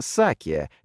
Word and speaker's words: саке: Тихоокеанский лосось называется саке, как местная водка, но с ударением на первый слог.